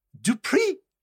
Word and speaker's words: Dupree: So his name is Max Dupree. The emphasis on the pre. – Wait, really? – Yep. – Oh, so it's got an upward Max inflection Dupree.